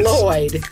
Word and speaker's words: Lloyd: Put your tongue where you're going to about to say sugar and then instead blow out on the sides. – Say Lloyd.